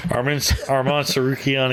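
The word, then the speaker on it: Arman Tsarukyan: Armand Tsarukyan against Mateus Gamrot.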